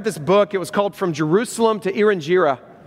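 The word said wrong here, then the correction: Irian Jaya: Erinjira, I don't know if I'm saying that right. Erinjira? Erinjaya, thank you.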